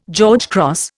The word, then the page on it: George Cross: Indian